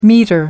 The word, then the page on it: metre: American English